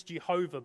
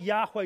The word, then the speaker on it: Yahweh: Older generations use Jehovah, but scholars think Yahweh is probably the closest pronunciation we can get.